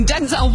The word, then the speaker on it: Denzel: Oddly enough, we're all pronouncing Denzel Washington's name wrong. – Oh, no, you didn't. – He says it's pronounced Denzel, but even he calls himself Denzel.